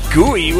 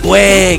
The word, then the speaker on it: güey: No mames, gooey. What the hell is that gooey mean? – It's the gooey part where I'm like, güey, cabrón, güey. – Güey, that's how you say it.